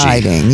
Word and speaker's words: Hiding: No G. Silent G. – Hiding. Yeah, you gotta do the slang thing.